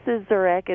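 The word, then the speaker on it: Szczurek: Teresa Cizurek. It's S-Z-C-Z-U-R-E-K.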